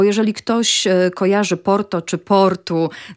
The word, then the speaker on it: Porto: Dlatego my tak naprawdę to nie jesteśmy, jak my mówimy o sobie, mieszkańcach Porto, to nie mówimy nawet, że jesteśmy Porto, tylko my jesteśmy tak naprawdę Portu,